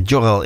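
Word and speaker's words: Görel: En Jorel, ik schrijf je eigenlijk met een G, maar in het Zweeds spreek je de G als een J uit.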